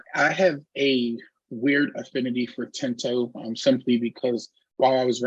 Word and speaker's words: Tinto: Tinto. Vincent Tinto.